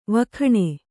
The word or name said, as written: ವಿಗುಣ